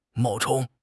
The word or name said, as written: 冒充